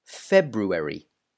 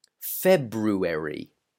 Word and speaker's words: February: We tend to ignore the R, the first R. So if you listen to a native pronounce this word, they will probably say February, not Febru, but Febu, February.